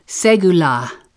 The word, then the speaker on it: segula: Segula. This special Hebrew word is one of my favorites, pronounced segula. Say it out loud right now.